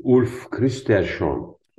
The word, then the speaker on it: Ulf Kristersson: Ulf Kristersson. Sen bilirsin bunları telaffuz etmeyi Ömer. – Sen de doğru telaffuz ettin.